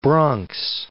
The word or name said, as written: Bronx